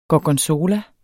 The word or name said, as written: gorgonzola